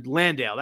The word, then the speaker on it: Landale: I'm sorry, Landale. That's how you say his name.